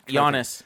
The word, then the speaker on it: Giannis: Giannis. – To, Giannis. – You don't pronounce the G.